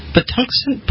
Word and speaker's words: Patuxent: And a senior advisor to the executive director of the Patuxent Partnership.